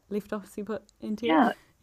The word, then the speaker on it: occiput anterior: An hour and a half before my daughter was born, she was in the left, I'm going to pronounce this wrong, occiput anterior. Is that right? Left occiput anterior? – Yeah.